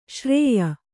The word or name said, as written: ಶ್ರಾಯ